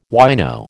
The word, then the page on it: wino: American English